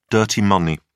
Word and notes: dirty money: British English

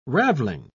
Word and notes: ravelling: British English

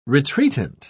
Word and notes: retreatant: British English

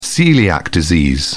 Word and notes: celiac disease: British English

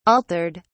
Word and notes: altered: British English

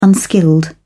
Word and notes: unskilled: British English

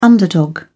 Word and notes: underdog: British English

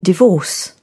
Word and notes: divorce: British English

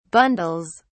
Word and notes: bundles: British English

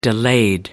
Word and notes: delayed: American English

